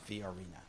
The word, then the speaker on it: Fiorina: Sorry? What? – Fiorina. – Fiorina. – Oh, what did I say? – Yeah. I'm always having to correct pronunciation of our guest. – Our guest is Professor. – Yeah, pronounce all the vowels.